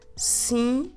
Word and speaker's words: cinzento: So we have the word for gray is cinzento.